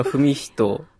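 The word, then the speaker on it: 史人: 文人と言います。はい、歴史の史にですね、人と書いて文人と言います。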